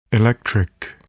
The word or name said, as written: electric